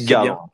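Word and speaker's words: Gavere: D'ailleurs, on se posait la question de comment prononcer Gavert. Il s'avère qu'en France, ça se dit Gavre.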